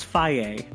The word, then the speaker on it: Foege: I picked world famous man William Foege. – He made the yogurt, right? – Yes. – It's actually pronounced Faye.